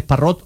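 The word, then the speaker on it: Parrott: Es Parrot, lo pronunciáis indistintamente. Parrot y Parro, pongo las tildes para una mejor comprensión. Primero porque es irlandés y fundamentalmente porque lo ha dicho el propio jugador.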